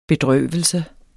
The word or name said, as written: bedrøvelse